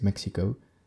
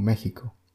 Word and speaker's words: México: No se pronuncia X o sea, no se dice México, como lo pronunciaría en inglés, México, sino que se pronuncia como una ja, como México.